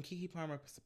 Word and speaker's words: Keke Palmer: And Keke Palmer performed too.